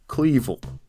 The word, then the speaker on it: Cleavel: And his name rhymes with evil. Cleavel. Evil Cleavel. He could have been a daredevil, but he chose to be a mining aficionado.